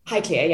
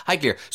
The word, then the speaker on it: Highclere: Highclere, yeah. – Yeah, Highclere.